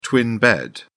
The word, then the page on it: twin bed: British English